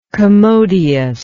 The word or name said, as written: commodiousness